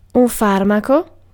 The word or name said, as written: medicine